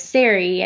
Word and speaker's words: Sari: And so since, gosh, what would that be, like 98, I've been saying it's Sari, not sorry.